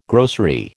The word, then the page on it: grocery: American English